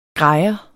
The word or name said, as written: grejer